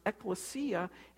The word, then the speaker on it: ekklesia: Even the word church in the Greek is a word that's pronounced ekklesia, and it just simply means a gathering of people.